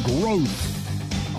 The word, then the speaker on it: Groth: Hey, Growth Law Firm, pronounced Growth, although they're spelled Groth, G-R-O-T-H.